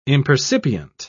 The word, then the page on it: impercipient: British English